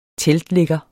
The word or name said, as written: teltligger